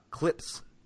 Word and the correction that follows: Klipsch: Oh, Klipsch.